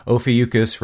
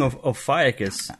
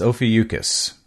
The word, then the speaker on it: Ophiuchus: And if you do need a reminder as to how to pronounce it, Ophiuchus rhymes with mucus. So for me, that's kind of what helps me remember that. – I've been saying Ophiuchus. – I used to call it that, but yep, it's Ophiuchus.